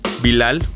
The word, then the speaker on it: Bilal: Bueno, pues si no, esto es eh, una de las canciones de su último disco, de The Roots, en la que participan Bilal, Bilal, pronunciado correctamente, y Greg Porn.